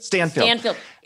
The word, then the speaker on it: Stanfield: Lakeith Steinfeld? Stanfield. – Stanfield.